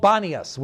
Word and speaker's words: Banias: Today, the place is called Banias with a B. The reason being is it's actually Panias. Okay, that's its actual name, Panias. Arabs cannot pronounce P in speaking Arabic. Okay, so they cannot pronounce P, so they pronounce P as a B. So that's why it's called Banias, because Arabs can't pronounce it correctly.